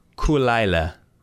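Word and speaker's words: Caol Ila: And this one is pronounced Kulaila. – Kulaila. – It's two words. Kulaila. Kulaila. – Kulaila. – It almost feels Jamaican when you say it like that. Oh, it's Kulaila, man. But it's spelled C-A-O-L. – Cool. Space. – Space. I-L-A. Isla.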